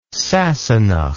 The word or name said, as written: sassenach